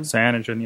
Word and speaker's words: Cyanogen: Is it Cyanogen? – Cyanogen, yeah. – Yeah. So it's incredibly customizable. It just it looks interesting and fantastic, and it might be time for a change. – Cyanogen is like the kind of alt.